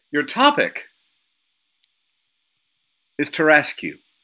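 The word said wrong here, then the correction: Tarasque: Excuse me? – Tarascu. – Tarascu? – Bless you. – Tarascu. – It's pronounced Tarask. – Tarask, excuse me.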